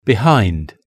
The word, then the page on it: behind: British English